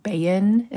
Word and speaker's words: Bahan: Nobody knows how to pronounce it. Except, you know, like 5 Irish descendants in the world. My name is Monica Bahan is how you say it. Bahan, it's B-A-H-A-N.